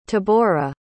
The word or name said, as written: tabora